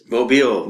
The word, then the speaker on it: Mobile: or how do you say it? – Mobile. – Mobile, okay, there you are. The proper pronunciation there.